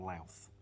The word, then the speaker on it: Louth: He said, for future reference, Luth is pronounced like mouth, so it's actually Sunshine on Louth.